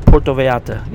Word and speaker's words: Puerto Vallarta: Puerto Vallarta.